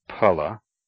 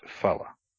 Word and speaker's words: phala: Sanskrit ph, pa, is never pronounced as f, but as aspirated p. The word for fruit, phala, is not pronounced phala.